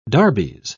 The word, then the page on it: darbies: British English